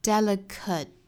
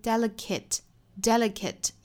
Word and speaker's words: delicate: 那 delicate 这个词后面的这个字母 a 它有两种发音，它可以发成一个 schwa sound、这个音 delicate，也可以发成一个 a 的音 delicate 也是可以的。